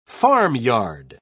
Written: farmyard

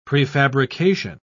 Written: prefabrication

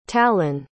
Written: tallon

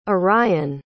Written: orien

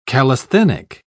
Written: calisthenic

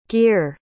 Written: geer

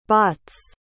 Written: Botts

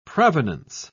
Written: provenance

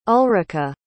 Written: ulrika

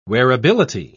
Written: wearability

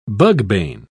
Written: bugbane

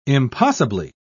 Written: impossibly

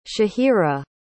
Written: shahira

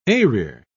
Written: eyrir

